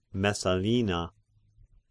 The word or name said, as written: Messalina